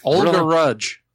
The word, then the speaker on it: Olga Rudge: So Ola Ruddage? Ola Ruddage died at 100. – How did you butcher that name? – Olga Rudge.